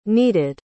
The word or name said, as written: needed